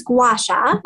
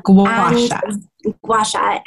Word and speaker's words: gua sha: So it's gua sha. Gua sha.